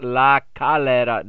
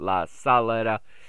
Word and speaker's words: La Calera: It is pronounced La Calera, not La Salera. So it would be an S if it was Salera.